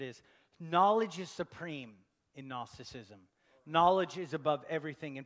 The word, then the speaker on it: Gnosticism: Say Gnosticism. Gnosticism. It has a G on the front, but the G is silent.